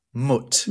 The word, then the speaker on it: Mutt: And he saw, and actually, the name written on it is Mutt. – Mutt. – Mm-hmm. But I'm pronouncing it like that because the German word for poverty is Armut.